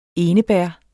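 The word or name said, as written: ENEBÆRRET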